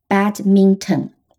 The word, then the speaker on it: badminton: 那 bad mint 不好的薄荷后面再加上嗯 o n，那么就变成 badminton。这里的 o n 发成 n。和我们平常很敷衍的回答别人时的“ 嗯” 读音是一样的。